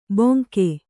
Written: ಬೊಂಕೆ

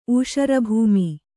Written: ಊಷರಭೂಮಿ

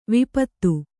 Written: ವಿಪತ್ತಿ

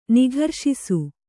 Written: ನಿಘರ್ಷಿಸು

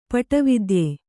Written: ಪಟವಿದ್ಯೆ

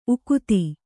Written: ಉಕ್ಥ

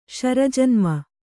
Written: ಶರಜನ್ಮ

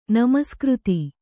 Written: ನಮಸ್ಕøತಿ

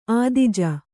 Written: ಆದಿಜ

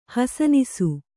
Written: ಹಸನಿಸು

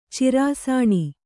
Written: ಚಿರಾಸಾಣಿ

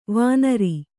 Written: ವಾಣರ

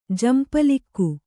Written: ಜಂಪಲಿಕ್ಕು

